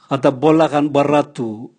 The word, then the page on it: gatabola gan bwaratu: Raga